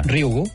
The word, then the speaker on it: Ryugu: Ryugu. És molt fàcil, com una I, Ryugu.